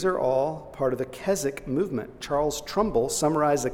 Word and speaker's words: Keswick: It's spelled like Keswick, if you ever see it, but it's pronounced like Keswick.